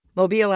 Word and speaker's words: Mobile: Mobile, Alabama.